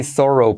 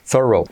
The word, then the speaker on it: thorough: He's a very thorough person. Como una persona muy meticulosa. Thorough. Para acordarte, acuérdate, por supuesto, de cómo es como decir zorro, pero con la pronunciación inglesa.